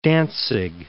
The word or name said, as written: Danzig